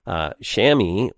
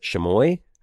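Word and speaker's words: Chamois: Shami, or the Stage Manager, which is the Stage Manager codename, or is it Shamoy? I think you can pronounce it both ways, actually. But Shamoy to be enabled for legacy devices.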